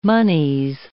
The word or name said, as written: monies